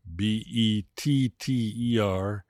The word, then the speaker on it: better: One pronunciation note remember that better, B E T T E R, is pronounced with a D sound like dog, better, better. He speaks better than I do.